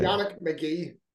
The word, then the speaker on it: Yannick Magee: Yannick Magee.